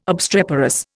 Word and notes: obstreperous: Indian

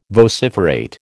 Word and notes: vociferate: American English

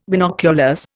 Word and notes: binoculars: Indian

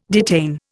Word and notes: detain: Indian

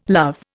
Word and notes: love: Indian

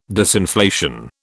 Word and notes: disinflation: British English

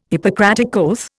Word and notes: Hippocratic oath: Indian

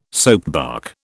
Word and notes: soapbark: British English

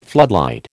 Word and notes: floodlight: American English

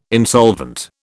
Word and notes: insolvent: British English

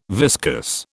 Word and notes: viscus: British English

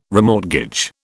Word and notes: remortgage: British English

